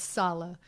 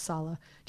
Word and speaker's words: Saleh: And uh, not that I'm one to uh, um, uh, pronounce properly because I just don't, but it's Saleh.